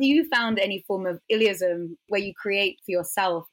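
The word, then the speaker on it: illeism: Illyism? – Illyism. Yeah. That's so funny. I've gotten so many questions about that word. It's I-L-L-E-I-S-M.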